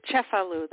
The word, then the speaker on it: Cefalù: It's called The Walk to Cefalù. That's the name of the city. It's a soft C. C-E-F-A-L-U.